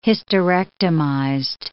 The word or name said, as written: hysterectomized